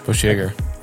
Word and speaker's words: Bushjager: Bushjager? Is that how you say it? – Bushjager. – I'm sorry. Is that right? – You've actually, I feel like you've nailed their name.